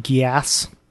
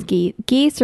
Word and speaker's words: Geass: That's how you say it, Geass. – I thought it was geese or geese.